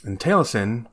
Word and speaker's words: Taliesin: And I hope I'm saying that right, because it's Welsh. I don't know Welsh very well. – You got me there. – T-A-L-I-E-S-I-N. Taliesin. – That sounds pretty close.